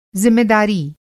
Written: ज़िम्मेदारी

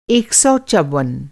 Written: चौवन

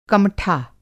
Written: कमठा

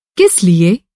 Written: किस

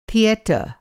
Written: थिएटर

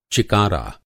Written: चिकारा

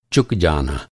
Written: चुकना

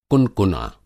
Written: कुनकुना

